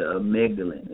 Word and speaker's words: amygdala: And people need to know what, with that being said, the amygdala.